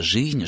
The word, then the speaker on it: жизнь: Ведь после шипящих «ж», «ц», «ш» мы произносим «ы», а не «и», а пишем «цитата», «жизнь», «живот», «шибко» и так далее.